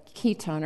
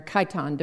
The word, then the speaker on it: chiton: As I was snorkeling, I held in my hand a little clamshell that had a tiny sea mollusk in it called a chiton or chiton, depending on which way you, if you want to go with the Greek pronunciation or the Latin.